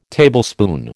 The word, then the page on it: Tablespoon: American English